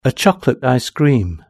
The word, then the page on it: a chocolate icecream: British English